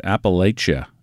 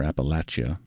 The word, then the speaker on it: Appalachia: On Folk Alley, and this is Emily Scott Robinson and Appalachia, or Appalachia, just kind of depends on where you're from is how you say it, I think.